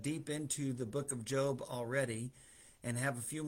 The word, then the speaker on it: Job: spelled like Job, J-O-B, but it's Job.